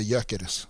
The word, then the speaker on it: Gyökeres: Jag har fått höra att man inte ska uttala G, att det är ett tyst, att han heter Gyökeres.